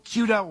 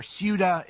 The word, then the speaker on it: Ceuta: So Ceuta, or Ceuta, is, I've heard it pronounced both ways, is a part of Spain that is actually on the northwesternmost part of Africa.